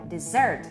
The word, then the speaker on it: dessert: Jeżeli powiem, że w wyrazie dessert akcentowana jest druga sylaba, to wtedy to oznacza, że właśnie na tę earth, na tą drugą sylabę jest właśnie ten nacisk.